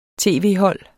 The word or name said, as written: tv-hold